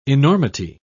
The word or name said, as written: enormity